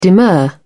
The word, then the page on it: demur: British English